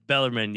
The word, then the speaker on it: Bellarmine: Yeah, Bellarmine.